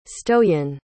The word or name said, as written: stoyan